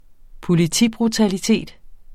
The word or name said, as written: politibrutalitet